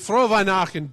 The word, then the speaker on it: Frohe Weihnachten: Frohe Weihnachten. See, he's so German he can't remember it. Now, he's Frohe Weihnachten.